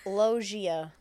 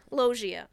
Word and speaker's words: Loggia: So, how do you say it? Loggia? Lugia? – Robert Luigi. Loggia. – Loggia. Robert Loggia was auditioning for Blue Velvet.